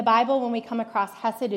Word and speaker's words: chesed: So the Hebrew word that linguists have translated as kindness is spelled C-H-E-S-E-D, and it's pronounced hesed. So if you're familiar with the spelling of Hallebred or Hanukkah, that silent C at the beginning will make a little more sense to you.